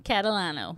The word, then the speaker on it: Catalano: Catalano.